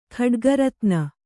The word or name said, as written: ಖಡ್ಗರತ್ನ